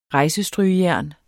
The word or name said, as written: rejsestrygejern